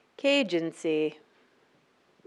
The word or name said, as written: Kagency